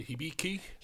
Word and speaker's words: Hibiki: The Hibiki.